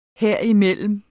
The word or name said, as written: herimellem